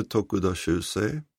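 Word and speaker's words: Tokuda Shūsei: Han heter Tokuda Shusei, född 1872, dog i november 1943.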